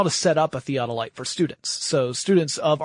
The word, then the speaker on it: theodolite: Originally, I watched the video just so I could figure out how to say theodolite properly instead of saying it like theodolite or something.